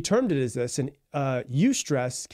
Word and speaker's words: eustress: The first one is called eustress, and it is EU stress. That's how it's spelled. And EU, like EU, basically what it means when you look at it, it's a Greek prefix, which means good. And so eustress basically just means good stress.